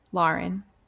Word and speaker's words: Lauren: Lauren. Like law. Like law. Like the word law, right? Or like lawyer. How do you say lawyer? – Lawyer.